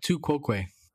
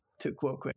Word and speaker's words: tu quoque: tu quoque. – Tu quoque.